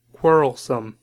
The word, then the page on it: quarrelsome: American English